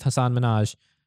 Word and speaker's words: Hasan Minhaj: And Patriot Act with Hasan Minhaj, as it's meant to be pronounced, as so I'm told, which is sort of Netflix's newest attempt at a political talk comedy show.